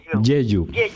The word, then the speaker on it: Jeju: Decía que son, bueno, pues son una comunidad de mujeres que viven, la mayoría de ellas, ¿no?, en la isla de Jeju, que también no sé si la pronuncio bien, ¿no? – Jeju.